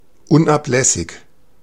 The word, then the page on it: unablässig: /ˈʊnaplɛsɪç/